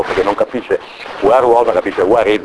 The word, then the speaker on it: werewolf: Però werewolf si pronuncia esattamente dove è il lupo, where is the wolf.